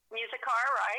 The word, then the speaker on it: Muzikar: Muzikar, right.